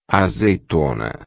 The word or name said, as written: azeitona